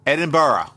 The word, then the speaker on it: Edinburgh: It's a lot of people it's easy to mispronounce. It's just the way it's read. So the way to, it's Edinburgh. – Edinburgh.